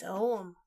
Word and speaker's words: Ogham: It's Oum. – But the GH. – I know, but it's always been pronounced Oum to me. Wait, okay, Tocloris. – Yeah. – Break our tie. Is it Oum? – Or Ogham. Or Ogham. – Because the GH is the hard English G.